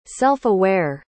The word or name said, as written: self-aware